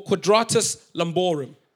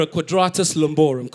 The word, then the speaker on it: quadratus lumborum: Which is short from, for quadratus lumborum. You know your boy spent hours Googling how to pronounce that. I was in the mirror, quadratus lumborum, quadratus lumborum.